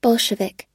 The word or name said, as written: bolshevik